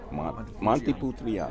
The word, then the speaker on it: Montepulciano: Montepulciano.